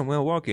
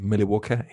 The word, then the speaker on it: Milwaukee: They're like, yeah, Milwaukee. And he was like, actually, that's a Native American word, which is correctly pronounced Milwaukee.